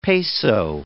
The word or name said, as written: peso